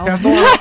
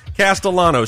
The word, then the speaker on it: Castellano: So, without further ado, I would like to introduce my guest, Liz Castanello. Good morning, Liz. – Good morning. – Did I say it right? – Castellano. – Castellano. Castellano. – C-A-S-T-E-L-L-A-N-O?